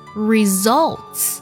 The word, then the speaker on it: results: 还有这个单词 results。 s u l 这个音节在读的时候读作 zol，要特别注意字母 l 呢和 tell 一样，发的是舌侧音重音，读作 zol，不要读作 zor，这样就错了。